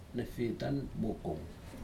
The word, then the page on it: nefitan mokom: Aneityum